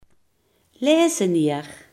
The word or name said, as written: katheder